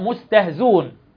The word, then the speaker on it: مستهزئون: الطريقة الثانية أو الكيفية الثانية الحذف أو الإسقاط، وذلك بحذف الهمزة في قولهم مستهزئون تقرأ مستهزون.